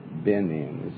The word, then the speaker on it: Benin: That sounds like Benin, not Benin. Benin. Isn't that the way you pronounce it? – Benin.